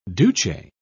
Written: duce

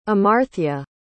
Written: amarthya

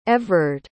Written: Evrard

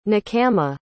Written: nechama

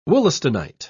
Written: wollastonite

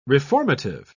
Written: reformative

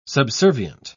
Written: subservient

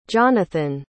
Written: jonathan

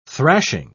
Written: thrashing

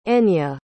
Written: enye

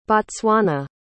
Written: Botswana

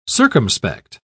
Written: circumspect

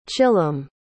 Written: chilam

